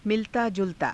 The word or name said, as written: milataa_julataa